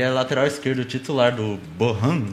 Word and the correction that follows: Bochum: o Bochum.